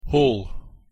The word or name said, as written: hall